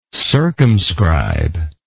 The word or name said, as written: circumscriber